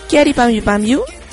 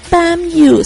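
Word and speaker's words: Kyary Pamyu Pamyu: Pero bueno, tuvimos música de Kiari Pami Pamiu, que por acá nos está diciendo Rodo en el muro del Facebook de Kakoi Radio. No se pronuncia Pamiu, se pronuncia Kiari Pami Pami.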